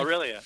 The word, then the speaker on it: Aurelia: But there was an article recently that you contributed to, and one of the cars that you were picked was, let's see if I pronounce this right, Aurelia, is that my pronouncing it right? – Aurelia, yep.